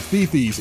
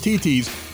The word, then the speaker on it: Thithi: It's spelled T-H-I-T-H-I. It looks like Thithi's. It's pronounced T.T.'s.